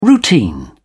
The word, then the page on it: routine: British English